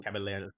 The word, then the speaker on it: Caballeros: Caballeros.